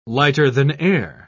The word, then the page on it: lighter-than-air: British English